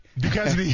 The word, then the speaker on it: Duquesne: Duquesne. – Yeah, there you go. Kenny Duquesne. I like that, man. It's going to kill Kenny Duquesne shirt going. – Hey, you'll never forget it now. Kenny Duquesne.